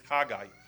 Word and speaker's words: Haggai: The book of Haggai, I pronounce it Haggai, as it is in Hebrew, because Hag is a festival, and I is like pronoun, meaning my, yeah?